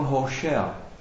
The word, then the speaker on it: Hošea: To jméno Ozeáš znamená, ono by se správně spíš mělo vyslovovat ne Ozeáš, takhle to máme jako v češtině už od mnoho, možná století, takhle nastavený, ale správnější vlastně překlad by měl být, nebo to, jak se to vysloví, jako Hošea.